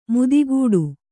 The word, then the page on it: ಮುದಿಗೂಡು: mudi gūḍu